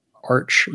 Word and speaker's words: Arch: In the Java ecosystem, there's a project called Arch Unit. I think it's like short for architecture, so I'm never sure how to pronounce it, but it's ARCH.